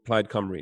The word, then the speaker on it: Plaid Cymru: And the party that you're running for is called Plaid Cymru, is that right? – Correct. I'm really impressed you pronounced it right.